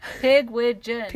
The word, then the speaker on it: Pigwidgeon: Pigwidgeon. – Pigwidgeon. All right. – Yeah, the last five letters are all gin.